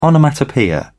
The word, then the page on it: onomatopoeia: British English